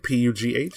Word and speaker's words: Pugh: Um, with uh, Steve Pug, I think P U G H. – Oh, i I think it's Pugh.